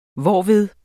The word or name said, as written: hvorved